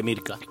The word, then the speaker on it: Meerkat: La aplicación se llama Mirka. Se escribe Meerkat. Pero se pronuncia Mirka.